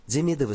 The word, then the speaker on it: Демидов: Демидовы, ставшие в Италии князьями Сан-Донато Демидов, с ударением на первый слог, не могли не поразить воображение жителей Апеннинского полуострова.